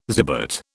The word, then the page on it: zibet: British English